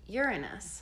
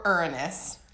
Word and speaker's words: Uranus: It is pronounced Uranus. – Or Uranus. I grew up calling it Uranus.